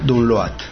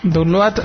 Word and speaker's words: download: Grupo M-A-D-S. – M-A-D-S, Maths. – Maths, eso. Punto .org, lo que he dicho yo. Barra download. – Download acabó en D. – Eso es, download. Porque es que parecía una T, lo último tuyo.